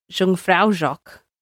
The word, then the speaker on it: Jungfraujoch: Up to Jungfraujoch. – That's a perfect pronunciation. – Up to Jungfraujoch, right to the top, carvings and caves, glaciers, mountains, the lot.